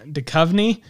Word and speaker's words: Duchovny: Duchovny. – Duchovny. Yep. – Why is there an H there? There's no need for an H. The H is silent.